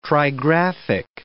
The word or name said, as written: trigraphic